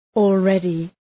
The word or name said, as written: already